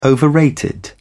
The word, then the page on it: overrated: British English